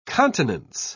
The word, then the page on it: continence: British English